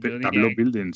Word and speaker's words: tableau building: Ah, pues tableau building, sí, tableau building, lo he dicho bien. He dicho table building, pero.